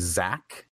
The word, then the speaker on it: Xaq: My personal favorite is still, it was a kid named Zach. – Okay. – But it's spelled X-A-Q. – X-A-Q? – X-A-Q. – What the hell is that? Is it? – That's how you spell Zach.